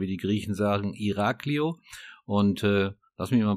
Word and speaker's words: Iraklio: Also, wie, ich habe es gerade schon gesagt, Iraklio wird es in Griechen ausgesprochen, ist die größte Stadt auf Kreta, und die fünftgrößte Stadt in Griechenland.